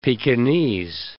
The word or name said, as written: Pekinese